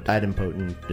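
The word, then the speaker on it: idempotence: Idempotent is the other way to pronounce it. – Oh, well, in English, we emphasize the second syllable in the word.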